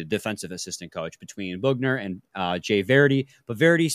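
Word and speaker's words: Boughner: He joins Bob Bugner. Bugner, guys. Bugner, not Boner. Bugner. Even though it totally looks like it should be pronounced the former, but it's Bugner.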